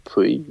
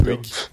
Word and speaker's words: Puig: Om inte Stosher åker ut mot Monica, hur uttalas det nu? Monica Puig? Puig? Ja, Puig, inte Puig.